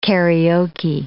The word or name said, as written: Karaoke